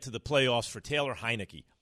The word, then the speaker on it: Heinicke: Heinicke. Did I say it right? – Yeah. Taylor. Yeah. – Taylor Heinicke.